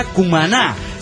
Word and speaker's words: Cumaná: Cumaná se pronuncia Cumaná. Seguro no acentuó quien envió este mail.